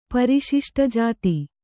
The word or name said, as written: ಪರಿಶಿಷ್ಟಜಾತಿ